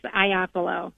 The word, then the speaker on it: Iapolo: Did I say it correctly? – Well, you were close. It's Iapolo. – Iapolo. Okay, wrong emphasis Iapolo. On the wrong syllable, but thank you.